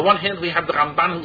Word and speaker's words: Ramban: The Ramban, the accent is always on the second syllable. In the Rambam, the accent is on the first syllable.